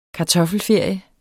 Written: kartoffelferie

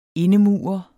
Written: indemure

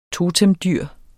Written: totemdyr

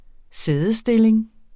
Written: sædestilling

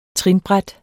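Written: trinbræt

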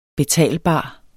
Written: betalbar

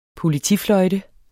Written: politifløjte

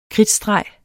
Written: kridtstreg

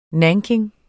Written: NANKIN

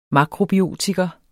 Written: makrobiotiker

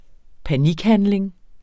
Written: panikhandling

